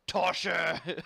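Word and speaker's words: tosher: Tosha! – Yeah, and you have to say it like that. Tosha!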